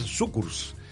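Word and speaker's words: Cukurs: Kukurs. En realidad se pronuncia, en letón se pronuncia Sukurs y quiere decir azúcar.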